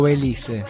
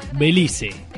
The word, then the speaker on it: Belice: ¿Belice o Belice? – Belice. – Belice. – Belice. Yo le voy a decir Belice.